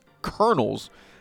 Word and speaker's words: Kernels: There was a high A team with the Twins, the Cedar Rapids Colonels, not like military colonels, but actually spelled phonetically, unlike Colonel. David, like corn kernels.